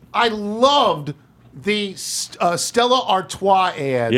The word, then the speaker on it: Stella Artois: She correctly pronounces Stella Artois.